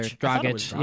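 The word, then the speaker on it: Dragić: Dragic.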